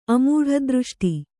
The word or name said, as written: ಅಮೂಢದೃಷ್ಟಿ